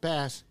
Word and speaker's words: Bass: She said, it says right here, oh, and the correct pronunciation of Bass is with a short A sound, right? Ah. – Where's that? – As in the type of fish.